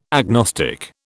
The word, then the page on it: agnostic: British English